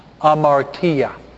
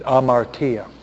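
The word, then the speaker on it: Amartia (ἁμαρτία): Amartia. The H is silent, it's not aspirated. Amartia.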